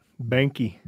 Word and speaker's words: Boehnke: Bonke? – Banky. – B-O-E-H-N-K-E.